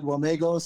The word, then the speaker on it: Wamego: He's going to help us divide and discern pastor of Mount Calvary Lutheran Church in Wamego, Kansas. Am I saying that right? Wamego? Well, well, Wamigo is how I see it, but I hear it uh, said Wamigo.